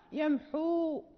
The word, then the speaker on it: يمحو: يعني مثلا ويمحو الله، يعني هنا لا ننطق بالواو في الوصل، نقول ويمحو الله، يعني كأنها حاء دون واو، ولكن حين نقف نثبت تلك الواو الموجودة في الخط، يمحو، يعني نقف بحركتين الموجودة في ذات الواو، لأن الواو من حروف المد.